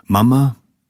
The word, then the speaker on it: Mama: Mama, mit Betonung auf dem ersten A,